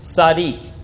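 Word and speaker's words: tzadik (צדיק): It comes from the Hebrew word and various words related to it, pronounced tzadik.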